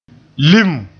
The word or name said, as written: lim